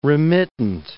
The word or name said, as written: remittent